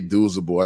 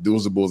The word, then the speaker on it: Douzable: It's Leger Douzable. I think Douzable is a lot easier when you look at it phonetically on paper to say than Leger.